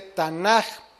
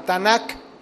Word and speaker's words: Tanaj: Pero se dice Tanaj, ¿por qué? Porque la primer letra de la palabra Ketuvim, que es K, se pronuncia como J y no como K. Ketuvim. Entonces, por eso es que se conoce como Tanaj y no como Tanak. Ajá.